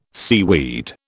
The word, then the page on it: seaweed: British English